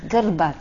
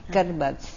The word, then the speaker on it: Grbac: G-R-B-A-C. Gerbac. It's a soft C at the end, Garbac.